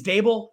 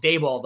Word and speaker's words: Daboll: And already Brian Daboll has come out and said, I think it's Daboll. That's how you say it. I should probably know the pronunciation before we start. It's Daboll, though.